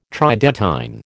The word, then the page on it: Tridentine: American English